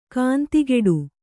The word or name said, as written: ಗಂಧಕುಟಿ